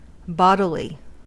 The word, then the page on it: bodily: American English